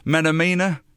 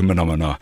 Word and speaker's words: Menomena: Now, the amount of times that I've pronounced this wrong, M-E-N-O-M-E-N-A, Menomina. Sounds a bit more like it, doesn't it? But no, it's as in the Kermit, the frog, Menomina. So it's Menomina.